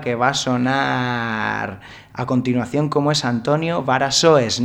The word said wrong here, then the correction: Variações: No sé si lo he dicho bien. – Variações. – Variações.